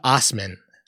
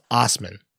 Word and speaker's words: Assman: This is, uh, let's talk about Dave Osman, whose last name is spelled A S S M A N. It's pronounced Osman.